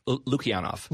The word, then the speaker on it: Lukianoff: Lukianoff. – Lukianoff. Thank you very much. I missed somewhat of a syllable there.